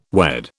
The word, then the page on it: wed: British English